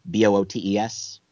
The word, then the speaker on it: Boötes: We've got a 1996 rock anthem, an astronomical object in the constellation Boötes, or in that region, Boötes, right? – Right.